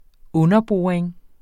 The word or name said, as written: underboring